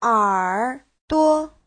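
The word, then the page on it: erduo: er3duo5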